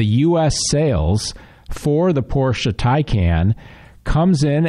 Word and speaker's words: Taycan: It's pronounced Taycan, even though it's spelled T-A-Y-C-A-N.